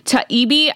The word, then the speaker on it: Taibbi: It is Taibbi. Taibbi. I'm pretty sure that that is what it is.